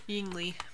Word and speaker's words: Ying Li: So the fight scene between Yang Li, is that how you pronounce it? And Wenwu. – Ying Li.